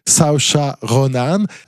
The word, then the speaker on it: Saoirse Ronan: Son nom, pour le prononcer correctement, puisqu'il vient d'Irlandais, c'est Saoirse Ronan.